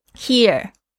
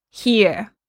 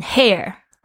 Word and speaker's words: hear: Hear, hear，注意一下，听是 hear，因为有一些我的学生呢会念成 hair，哦，不是 hair，hair 是头发，hear 才是听到。